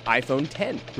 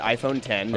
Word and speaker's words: iPhone X: It's pronounced iPhone X. – Really? – Yeah, okay. They clarified it yesterday. It's not X. It's iPhone X.